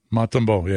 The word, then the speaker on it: Montembeault: And how do you pronounce the Canadiens goalie's last name? Montembeault? – I'm going to go with the proper pronunciation from our next guest, Joe Beninati. I'm going to go with Montembeault. – Montembeault, yeah.